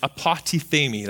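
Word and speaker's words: apotithemi (ἀποτίθημι): And my Strong's Concordance tells me that it's pronounced apotithemi.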